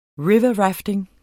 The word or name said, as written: riverrafting